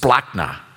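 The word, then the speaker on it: splachna (σπλάγχνα): It's pronounced splachna, which sounds more like a, I don't know, a German dessert.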